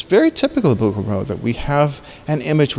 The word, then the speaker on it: Bouguereau: Bouguereau. – Bouguereau. – Bouguereau. Absolument. C'est parfait. Bouguereau.